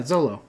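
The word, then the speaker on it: Xolo: I thought it was Zolo. – Zolo would start with a... I think X is a sh sound.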